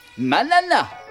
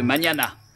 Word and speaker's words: mañana: Sans le tilde, le mot devrait se prononcer manana au lieu de mañana.